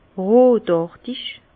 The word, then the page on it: rotàrtig: Bas Rhin; Herrlisheim